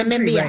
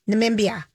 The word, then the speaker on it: Namibia: No, Namibia. – Right. – Namibia.